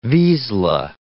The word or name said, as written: vizsla